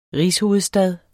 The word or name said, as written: rigshovedstad